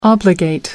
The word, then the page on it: obligate: British English